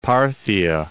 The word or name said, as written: Parthia